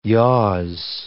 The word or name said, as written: yaws